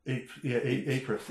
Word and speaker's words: Ypres: Ypres? Is that how you pronounce it? – Ypres. Yeah, the battle of...